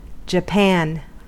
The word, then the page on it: japan: American English